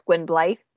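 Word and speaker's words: Gwynbleidd: Yeah, so for him it's Gwynblythe because it's double D.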